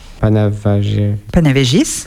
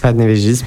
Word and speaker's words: Panevėžys: Après, à la plage, il y a Palanga, où je suis allé, Alitus, Šiauliai, Panevėžys. – Panevėžys, merci. – Ah, merci pour la prononciation de ville, parce que c'est vraiment une prononciation lituanienne.